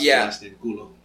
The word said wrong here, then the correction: Coelho: How do you pronounce it? – Kulo's butt. In Spanish. – In Spanish. – Coelho. Coelho.